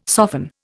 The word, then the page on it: soften: Indian